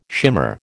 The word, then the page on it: shimmer: American English